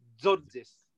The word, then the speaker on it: Tzortzis: But basically, you put the T and the Z together quite fast, so it's like Tzortzis.